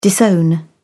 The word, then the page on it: disown: British English